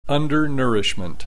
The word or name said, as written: undernourishment